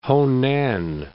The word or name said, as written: Honan